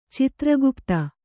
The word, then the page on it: ಚಿತ್ರಗುಪ್ತ: citra gupta